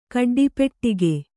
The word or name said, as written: ಕಡ್ಡಿಪೆಟ್ಟಿಗೆ